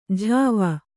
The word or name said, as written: ಝಾವ